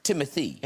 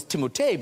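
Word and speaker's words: Timothée: It's pronounced Timothy. – It's whatever you'd like. – Because okay. I always thought, I don't know if you guys, I thought it was Timotei because of the double E's at the end.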